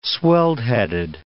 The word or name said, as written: swelled-headed